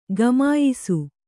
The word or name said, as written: ಘಮಾಯಿಸು